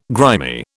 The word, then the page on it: grimy: British English